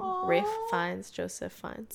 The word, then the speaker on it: Ralph: Ralph, but then but it's pronounced Rafe, Rafe.